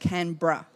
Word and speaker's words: Canberra: So Canberra, as Americans would say, is just Canberra.